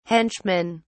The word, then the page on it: henchmen: British English